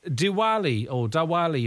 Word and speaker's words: Diwali: Diwali, if I'm pronouncing that correctly.